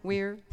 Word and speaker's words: Weir: Lake Wire? Weir? – Close enough.